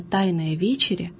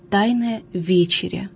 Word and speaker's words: тайная вечеря: Суетное, обыденное употребление выражения «тайная вечеря» недопустимо. Следует также обратить внимание и на постановку ударения в этом выражении. «Тайная вечеря», не «тайная вечеря». В слове «вечеря» ударение должно падать на первый слог.